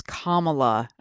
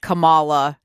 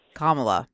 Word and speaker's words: Kamala: It is Kamala, okay? Not Kamala, not Kamala, Kamala.